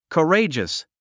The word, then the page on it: courageous: kəréidʒəs; カレイジャス